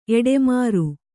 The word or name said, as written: ಎಟ್ಟಿಮರ